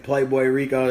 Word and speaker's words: Playboirico: Right, y'all can follow me on Twitter at Playboy Rico. That's boy with an I.